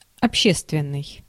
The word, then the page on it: общественный: /ɐpˈɕestvʲɪnːɨj/